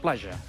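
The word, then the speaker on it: platja: Pronuncis així, sisplau, la plaja. – La plaja. – I la gent autòctona et farà una abraçada si t'escolta pronunciant-ho d'aquesta manera.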